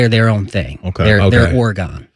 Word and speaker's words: Oregon: It's the exact same word. I just grew up saying that Oregon. Word is Oregon. – Right. Oregon. – We're right. – in Oregon, and that's the proper way to say it.